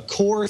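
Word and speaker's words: QOR: This is a Core, that's spelled with a Q, Q-O-R, a Core 32.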